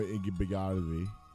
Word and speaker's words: Igbinoghene: All right, so you heard Noah Igbinogany. That's how you pronounce the name.